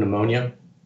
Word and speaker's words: pneumonia: And a little thing is the P was silent, you know the word pneumonia, the P is silent.